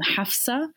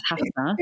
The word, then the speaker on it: Hafsah: So you would actually pronounce my name Hafsah in Arabic if you were really being pedantic about it. So I'm already doing you a favor by telling you to pronounce it as Hafsah. So, I mean, if we really can be a bit anal, I would even say to you, no, pronounce it that way.